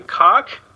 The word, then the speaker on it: Koch: I'm probably saying this completely wrong, and I'm not trying to make a pun, but Citizen Koch? – What? – K-O-C-H, is that Koch? – Koch. We have a TV personality called David Koch. I don't think he pronounces it Koch.